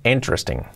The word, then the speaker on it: interesting: Cuidado con la palabra interesting, que es interesting, no es interesting. Interesting, ¿ok? Con acento en la primera sílaba.